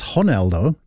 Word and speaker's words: Ronaldo: So earlier on, Cristiano Ronaldo, or apparently we're saying it wrong, it's Ronaldo, because he's Portuguese and the R is an H.